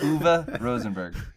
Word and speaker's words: Uwe Rosenberg: Uwe Rosenberg.